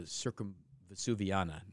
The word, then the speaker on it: Circumvesuviana: I'll say this wrong. The train is called the Circumvesuviana, not the circumcision.